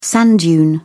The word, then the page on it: sand dune: British English